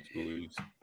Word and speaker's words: Ruiz: Am I saying it right? – Ruiz.